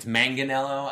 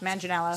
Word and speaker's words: Manganiello: I've heard people pronounce it with the hard G too, like it's Manganiello. I think. I don't know. – I think it's the soft Manganiello.